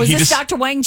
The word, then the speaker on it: Wang: You pronounce his name. You pronounce it's Dr. Wan is how you say his name. That is how you say his name. – But it has a G on it? – It's spelled like Wang, but you pronounce it Dr. Wan. – It's a silent G like lasagna.